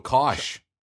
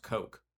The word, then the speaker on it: Koch: It's so funny because everyone says Kosh, and including me, or Kosh, Kosh or Kosh. And it's actually pronounced Coke.